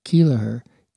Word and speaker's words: Keleher: If you're looking up Alex's name, his last name is spelled K-E-L-E-H-E-R, Kelleher, easy.